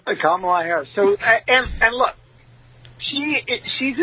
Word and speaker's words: Kamala: And by the way, just stop mispronouncing her name because they're going to start calling you a racist. Not that they don't already do. – No, it's Kamala.